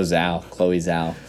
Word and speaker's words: Zhao: Zhao, maybe. Right. I don't know. – Yes, Chloe Zhao.